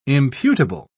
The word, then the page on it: imputable: British English